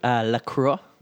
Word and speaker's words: La Croix: How do you pronounce it? – La Croix. – La Croix.